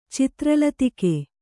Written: ಚಿತ್ರಲತಿಕೆ

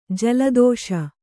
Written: ಜಲದೋಷ

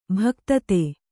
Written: ಭುಕ್ತಿದ

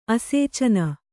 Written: ಆಸೇಚನ

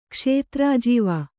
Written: ಕ್ಷೇತ್ರಾಜೀವ

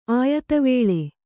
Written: ಆಯತವೇಳೆ

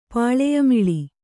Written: ಪಾಳೆಯಮಿಳಿ

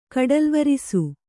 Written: ಕಡಲ್ವರಿಸು

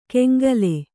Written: ಕೆಂಗಲೆ

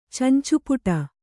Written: ಚಂಚೂಪುಟ

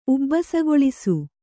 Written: ಉಬ್ಬಸಗೊಳಿಸು

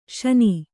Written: ಶೀನು

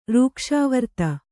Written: ರೂಕ್ಷಾವರ್ತ

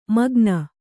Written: ಮಾಗಾನ

